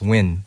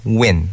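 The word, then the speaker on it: Nguyen: No, it's actually pronounced Win. – Win. – I get that all the time growing up in the States. – Yeah. – They always pronounce it Nugin, Nugayan. But it's Win, like Win.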